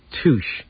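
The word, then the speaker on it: Tüch: Tooch. Is that right? – Tooch. – Tooch. Okay, from where? – Sable.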